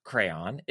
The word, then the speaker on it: crayon: Webster's Dictionary states the proper way to pronounce crayon is two syllables, crayon. However, individual and regional dialects have slightly different pronunciations.